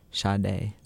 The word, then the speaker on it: Sade: And then before that was Sade. Is that how you say it? Yeah, with Pearls.